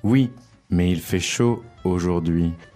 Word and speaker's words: aujourd'hui: Aujourd'hui. – Today. – That's it. – Let's focus on the pronunciation of that. Can you say it? – It's not that difficult. Aujourd'hui.